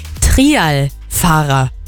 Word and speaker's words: Trialfahrer: Ja, weil man hat ja immer Trail irgendwie so im Kopf. Und jetzt wird das aber andersrum geschrieben und dann war ich ein bisschen verwirrt. Also Trialfahrer ist der richtige Begriff.